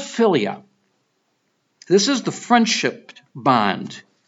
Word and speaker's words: philia: Pronounced philia.